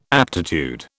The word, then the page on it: aptitude: British English